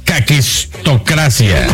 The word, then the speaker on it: caquistocracia: Así se pronuncia caquistocracia.